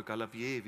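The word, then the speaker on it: Gabalier: Oder vielleicht haben manche von euch schon ein Lied von einem gewissen Schlagersänger Andreas Galabier oder Galabier, wie er ausgesprochen wird, gehört.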